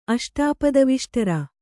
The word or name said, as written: ಅಷ್ಟಾಪದವಿಷ್ಟರ